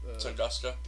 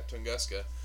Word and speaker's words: Tunguska: Tunguska. – Yeah, Tunguska.